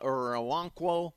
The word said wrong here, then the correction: Okoronkwo: I can never say his name. Okoronkwo.